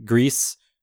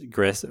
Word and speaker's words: Gris: My next video is on Grease. GRIS, Gris. I think most people probably pronounce it Gris in their head, but their Twitter handle said that it is actually pronounced Grease.